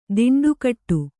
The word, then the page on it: ದಿಂಡುಕಟ್ಟು: diṇḍu kaṭṭu